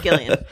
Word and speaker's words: Gillian: It's Gillian. Thank you. In this house, we pronounce the hard G.